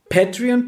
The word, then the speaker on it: Patreon: Ihr geht auf Patreon. Patreon schreibt man e also P-A-T-R-E-O-N.